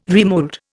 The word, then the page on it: remould: Indian